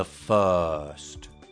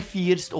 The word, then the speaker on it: first: The first. Giovedì. Thursday. Confermare. Confirm. Quello suono, uomo che sta morendo, è troppo importante in inglese, perché non è first o confirm, first, confirm.